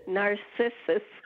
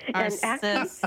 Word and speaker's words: Narcissus: Also, how do you say the name of this plant? It's a hoot trying to Google it and then finding narcissist come up every time. – Narcissus. – Okay. – Narcissus.